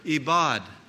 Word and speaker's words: ebed: The word ibad, or just pronounced bad, we'd call it bad, B-A-D, but it was E-B-E-D.